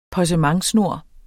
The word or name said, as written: possementsnor